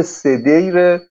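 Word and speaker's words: سدیر: معربش تلفظ میشه صدیر بر وزن حسین، ولی اینجا صدیر خونده میشه.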